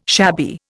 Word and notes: shabby: Indian

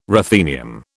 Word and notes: ruthenium: British English

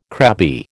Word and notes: crabby: American English

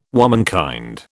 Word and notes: womenkind: British English

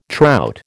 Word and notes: trout: American English

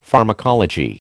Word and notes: pharmacology: American English

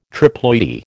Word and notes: triploidy: American English

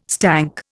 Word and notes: stank: Indian

